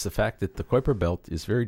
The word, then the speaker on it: Kuiper Belt: New Horizons is still out there, beyond Pluto, somewhere in the Kuiper Belt. Is that how you say it? – That's right.